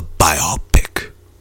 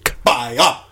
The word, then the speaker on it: biopic: This November, watch the biopic. – You're putting the stress on two of the syllables. Just need one of them. – Biopic.